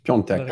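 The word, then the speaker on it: Piątek: How did you say his name? – Piatek. Piatek. – It's Piontek.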